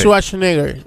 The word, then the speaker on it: Schwarzenegger: Schwarzenegger.